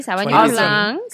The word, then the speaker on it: Sauvignon Blanc: So this is a Sauvignon Blanc. – Okay. – Did I say that right? – Si, Sauvignon Blanc.